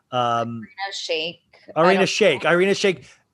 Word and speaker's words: Irina Shayk: And we got the big news story this past week that Kanye, for his birthday, him and Irina Shirik. How do I don't know how to say her name. – Irina Shayk.